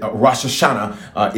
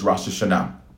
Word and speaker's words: Rosh Hashanah: All right, Rosh Hashanah, if you from the South, all right, but the appropriate pronunciation is Rosh Hashanah.